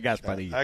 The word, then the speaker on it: Gasparilla: I think you mispronounced it as Gasparilla. – Gasparilla. – It's like a quesadilla, but a Gasparilla.